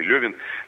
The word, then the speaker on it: Левин: И ведь не случайно, вот Лев Николаевич Толстой в своем романе «Анна Каренина», там есть персонаж Левин или Левин, по-разному произносится это имя, это и есть портрет вот князя Львова.